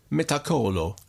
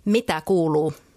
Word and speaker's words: Mitä kuuluu: Mitä kuuluu means how are you? – Mitä kuuluu.